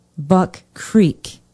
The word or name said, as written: buck creek